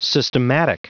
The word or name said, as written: systematic